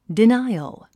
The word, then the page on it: denial: dináiəl; ディナイオゥ